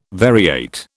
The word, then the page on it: variate: British English